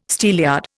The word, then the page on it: steelyard: Indian